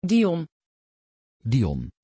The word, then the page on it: Dion: Dutch